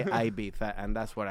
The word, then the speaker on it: Ibiza: Someone in Spain would refer to the island as Ibiza. – Yeah. – Yes. – Even though they'll still spell it as Ibiza.